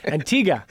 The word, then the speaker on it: Antigua: Antigua. – Antigua. The U is silent. Yeah. So for, you know, I don't know if anybody from Antigua is listening, but it's Antigua. – Antigua.